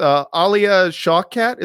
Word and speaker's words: Alia Shawkat: The opening of the movie starts with Alia Shawkat.